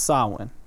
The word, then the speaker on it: Samhain: It's spelt Samhain, but it's pronounced Samhain, right?